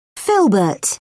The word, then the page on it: filbert: British English